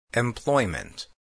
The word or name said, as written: employment